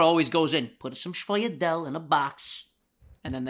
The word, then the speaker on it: sfogliatella: But every time you try to order, hey, can I get a Schwingadel, Schwoidel? They hit you with like a, how do you say it? – I'm pretty sure it's Schwoidel, but I'm basing that off of The Sopranos.